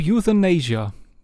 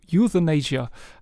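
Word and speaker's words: Youthanasia: Ich glaube Euthanasia. Weil das ist so ein bisschen, also Youth wegen der Jugend und das Amnesia ist ja dieses Krankheitsbild, dass man Sachen vergisst und darum geht es ja auch genau in dem Album.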